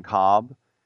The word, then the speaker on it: Kolb: I don't know what language the L is silent. Remember Kevin Kolb, Kevin Cobb?